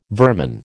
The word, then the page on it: vermin: American English